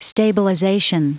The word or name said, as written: stabilization